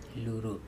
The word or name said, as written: luro